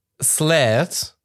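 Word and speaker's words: Sleat: Slayed, ausgesprochen halt. – Wie du es ausgesprochen hast, ja. – Mit so einem Strich über dem E. Slayed, ja.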